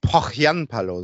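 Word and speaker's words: Pohjanpalo: Ja, vielleicht hat äh, Pojanpa, Pochjanpalo, so wird er ausgesprochen, seine, äh, seine Kontakte spielen lassen.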